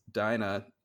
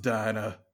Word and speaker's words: Dinah: However, when I did recast her, I recast her with a person who didn't know that Dinah's name was Dinah and not Dina. That comes up in the script a couple times, and well, that was that was a hectic phone call. I was like, hey, it's pronounced Dinah.